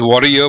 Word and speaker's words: Varrier: So her last name is spelled as V-A-R-R-I-E-R. And I'm assuming it is Warrior, but somebody misspelled her in her previous generations. And, you know, that wrong spelling is still continuing, is my guess.